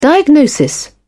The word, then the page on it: diagnosis: British English